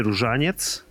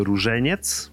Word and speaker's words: Różeniec: W każdym razie po polsku nosi nazwę z reguły arktyczny korzeń, bądź złoty korzeń, bądź różeniec górski. E, nie różaniec, tylko różeniec. Mm, arktyczny korzeń, ponieważ e, rośnie w rejonach arktycznych, e, głównie w Europie, również w Wielkiej Brytanii oraz no, ogólnie Eurazji. A różeniec, ponieważ przynajmniej jeżeli się przegryzie tą tabletkę na przykład, bądź e, powącha się ją, to ma taki różany aromat.